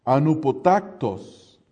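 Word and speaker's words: anupotactos (ἀνυπότακτος): En el idioma original es la misma palabra y es una palabra que se pronuncia anupotactos.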